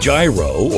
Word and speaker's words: gyro: How do you pronounce the word G-Y-R-O? Whether you say gyro or gyro, it doesn't matter.